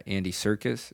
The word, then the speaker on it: Serkis: when Andy Serkis, is that how you say his name? – Yeah.